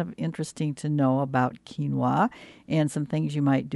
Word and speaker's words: quinoa: This morning, I'm going to talk about quinoa. I'm wondering if you've seen that word. It doesn't look like that. It's spelled Q-U-I-N-O-A, and it's pronounced quinoa, Q-U-I-N-O-A, quinoa.